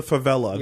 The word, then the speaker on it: favela: Thanks for warning me about the favela. – It's fine. All right. Yeah. Now you know. – Am I saying it right? – Yeah. Favela.